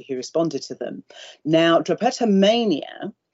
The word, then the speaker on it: drapetomania: I mean, I was wondering if you might be able, for example, to tell us about, is it pronounced drapetomia? – Ah, drapetomania. – Excuse me, yes.